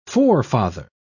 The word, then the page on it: forefather: British English